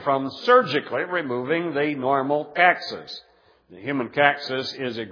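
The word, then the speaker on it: coccyx: I'm not sure I was pronouncing that word right before, but it's caxis is the way it's supposed to be pronounced.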